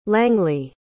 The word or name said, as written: langley